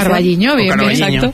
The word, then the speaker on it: Carballiño: O Carballiño, no lo sé pronunciar. – Carballiño, que también lo conoce muy bien Edgar, porque tu socia...